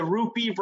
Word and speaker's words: Rupi: I love that Rupi rhymes with poopy.